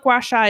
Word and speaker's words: gua sha: And I looked up how to say it, and that is how you pronounce it. Because I didn't want to sound like a fucking fool. So it's what gua sha